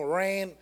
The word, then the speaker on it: reign: Rain, you know, rain, not R-A-I-N, R-E-I-G-N, rain.